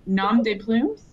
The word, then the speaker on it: nom de plumes: Nom de plumes? Did I say that right? – Yep.